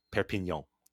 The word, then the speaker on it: Perpignan: And I just listened to someone say it's Perpignan. That's the pronunciation.